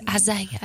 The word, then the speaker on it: Isaiah: Sorry, did I say it like I was from Texas? Isaiah. – That's it. Sorry. I just thought someone might even be like, wait, what? What, 53? – Yeah, no, I do feel like you turned a three-syllable word into one.